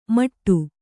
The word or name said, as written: ಮಟ್ಟು